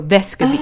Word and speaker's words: Vescovi: Not him, it's Santiago Vescovi.